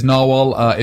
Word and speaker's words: narwhal: We've just had a big debate about the correct pronunciation of that. We have concluded it is Narwhal.